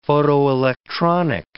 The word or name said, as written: photoelectronic